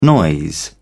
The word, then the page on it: noise: British English